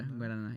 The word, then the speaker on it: Guaraná: And correct me if I butcher this name. Guaraná. – You said it right. – Yeah? – Yeah, Guaraná.